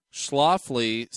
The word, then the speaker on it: Schlafly: It's the Schlafly. Am I saying that right? – Schlafly Special Release American India Pale Ale.